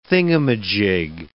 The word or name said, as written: thingamajig